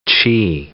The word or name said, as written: qi